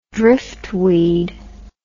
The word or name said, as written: driftweed